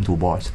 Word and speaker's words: Du Bois: 有冇 S 嘅佢個發音 Du Bois，因為佢好似係非裔嘅，佢自己阿爺係咪係非係唔係非佢阿爺係荷蘭人士嚟嘅，好似係荷蘭嘅非裔人士，跟住去咗美國咁樣嘅。